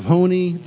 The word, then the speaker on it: Honi: And the only one that was left of that type was an old sage by the name of Honi, spelled H-O-N-I, pronounced Honi.